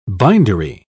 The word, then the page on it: bindery: British English